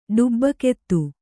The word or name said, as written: ಡಬ್ಬುಗಾತಿ